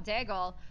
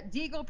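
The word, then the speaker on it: Deagel: You and I talk about Daigle, or is it Deagle or Daigle? I always Deagle. – Deagle. I always say it wrong.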